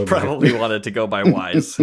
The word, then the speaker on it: Wise: How do you say his last name? Wise? – Wise. – Wise. He's from Indiana, so I didn't think it would go with the full German W, but you never know.